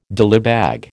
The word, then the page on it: dillybag: American English